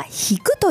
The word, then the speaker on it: 弾く: これは弾くと読みます。